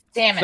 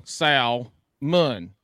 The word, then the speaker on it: salmon: Say the name S-A-L, Sal, Mun. You don't go Sal. – That's phonemically correct. – But exactly. It's not correct pronunciation. – Because it's please not an English-based word. So we don't go with our English-based It's salmon.